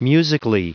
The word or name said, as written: musically